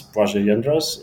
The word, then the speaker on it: Blazej Jedras: And that's my full name. That's Blazej Jedras. And you'll find in the show notes how that's spelled, because my pronunciation will not help you.